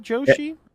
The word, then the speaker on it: Joshi: It was confirmed in this month's Doctor Who magazine that Bhav Joshi. – Bhav Joshi? – Yes. – It might be pronounced as the Mario character Yoshi, but it's probably Joshi, which is also a form of female Japanese wrestling.